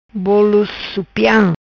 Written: Bolo Supiam

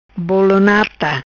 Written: Bolo Nata

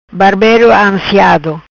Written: Barbero ansiado

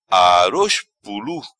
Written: Aroz Pulu

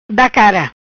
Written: Cara da

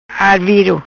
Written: Arviro

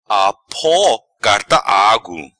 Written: Apo Carta Agu

